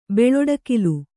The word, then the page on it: ಬೆಳ್ಳಡಕಿಲ್: kannada